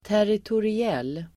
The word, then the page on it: territoriell: [täritori'el:]